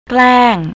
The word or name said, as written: klang